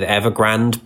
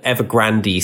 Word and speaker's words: Evergrande: Historically, I went with Evergrande, but Evergrande seems to have much more of a dramatic flourish to it. So I think I'm going to put the accent on the E going forwards.